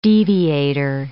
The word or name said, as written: deviator